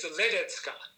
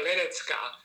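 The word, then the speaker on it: Ledecká: So again, the accent is on the first syllable. – Uh-huh. – And the C, and the C is a TS, so it's Esther Ledecka. And the little accent on the A at the end turns it into a sound.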